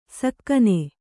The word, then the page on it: ಸಕ್ಕನೆ: sakkane